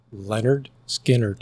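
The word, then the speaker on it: Lynyrd Skynyrd: Leonard Skinnerd.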